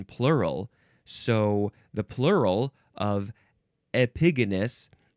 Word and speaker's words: Epigonus: Epigonus. Yeah, that's how you say it.